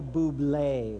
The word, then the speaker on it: Bublé: His name was Michael Bubbly. Is that how you say his name? Oh, sorry, Michael Bublé.